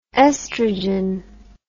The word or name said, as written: oestrogen